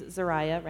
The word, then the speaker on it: Zariah: Her name is Zariah, rhymes with Mariah, but with a Z.